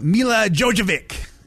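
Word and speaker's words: Milla Jovovich: We were just talking on last week's show about Mila Jovovich.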